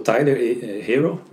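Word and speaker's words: Herro: Parece Erro.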